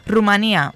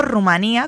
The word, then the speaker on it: Rumanía: Rumanía, más usado en América, sin acento, o Rumanía, con acento en la I, con tilde, acento gráfico, más usado en España.